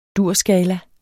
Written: durskala

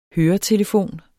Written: høretelefon

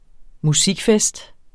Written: musikfest

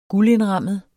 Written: guldindrammet

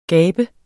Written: gabe

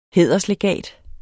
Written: hæderslegat

